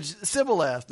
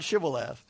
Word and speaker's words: Shibboleth: And they couldn't say it right. They said Sibboleth, not Shibboleth.